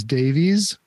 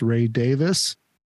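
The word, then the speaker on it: Ray Davies: And a lot of people think his name is Davies, but it's actually pronounced Ray Davis from the Kinks.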